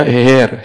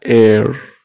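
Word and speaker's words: heir: English में अगर कोई पढ़े तो इसको पढ़ेगा हेयर, हेयर, पर इंग्लिश में प्रोनाउंसिएशन होता है एयर, एयर, जैसे ए आई आर एयर होता है ना।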